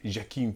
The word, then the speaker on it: Joaquin: It is pronounced Joaquin Phoenix.